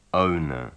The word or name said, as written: owner